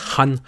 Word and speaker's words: Khan: Il y a un film qui va nous apprendre qu'il faut vraiment le prononcer Khan.